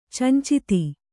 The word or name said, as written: ಚಂಚಿತಿ